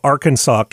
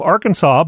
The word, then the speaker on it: Arkansas: In 1881, the Arkansas Historical Society put together a pamphlet called Fixing the Pronunciation of the Name Arkansas it found that Kansas is pronounced Kansas because that's a standard English way to say those syllables.